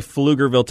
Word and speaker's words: Pflugerville: I am the leading basketball scorer for Pflugerville High School all time. Score for Pflugerville High School. – Pflugerville! – Mm-hmm. That'd be Pflugerville, Texas, spelled with a P-F.